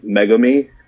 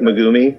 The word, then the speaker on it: Megumi: They pronounce it like Megumi, when it should be pronounced like Megumi.